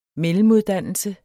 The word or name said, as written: mellemuddannelse